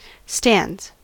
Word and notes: stands: American English

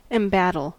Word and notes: embattle: American English

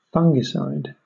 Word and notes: fungicide: British English

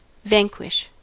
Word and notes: vanquish: American English

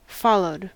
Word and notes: followed: American English

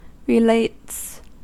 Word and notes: relates: American English

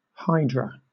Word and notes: hydra: British English

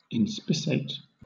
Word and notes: inspissate: British English

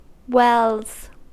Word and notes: wells: American English

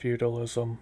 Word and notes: feudalism: American English

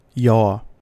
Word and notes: yaw: American English

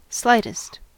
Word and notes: slightest: American English

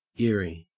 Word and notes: eyrie: Australian English

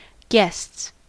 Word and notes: guests: American English

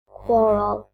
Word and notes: quarrel: British English

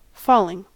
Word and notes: falling: American English

